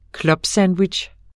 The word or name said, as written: clubsandwich